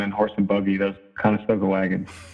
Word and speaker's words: Conestoga: So you attend Conestoga High School. Did I pronounce that right? – Conestoga, yeah. – Both. Conestoga. Like, uh, like Conestoga, like Conestoga wagons.